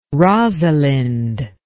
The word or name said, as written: rosalind